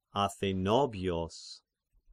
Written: Athenobios

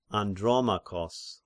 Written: Andromachos

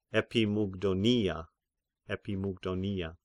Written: Epimygdonia

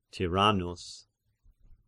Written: Tyrannus

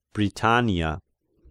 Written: Britania

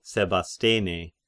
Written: Sebastene

